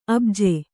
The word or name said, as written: ಅಭಿಜೆ